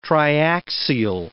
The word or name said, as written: triaxial